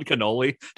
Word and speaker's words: Kenoly: Ron Canole? – That is. It's spelled K-E-N-O-L-Y. – Okay. – So it's like kid like... and the back part of holy.